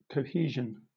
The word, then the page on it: cohesion: British English